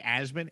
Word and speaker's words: Asman: It's just my name, Jake Asman, A-S-M-A-N. It's pronounced as in A-Z-M-I-N. Got to give that pronunciation tips to those out there.